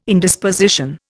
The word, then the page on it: Indisposition: Indian